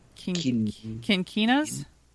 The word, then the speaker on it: quinquinas: I already forgot how to say the other word. Quinquinas? Yep.